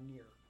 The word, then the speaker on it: Nir: And I am Nir.